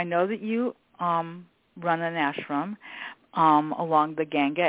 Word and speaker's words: ashram: So I know that you run, and I believe, correct my language here as well, is it pronounced ashram? Is that how you say that? – Ashram. Ashram. – Ashra? Okay. You know, ashram. – It's really funny. – Ashram with an M.